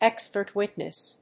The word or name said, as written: expert witness